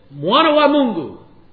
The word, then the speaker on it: Mwana wa Mungu: And I said, Mwana wa Mungu.